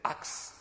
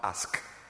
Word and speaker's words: axe: Is it axe or what? Axe. How do you pronounce it? The English? – Axe. – Axe. Not ask. Axe.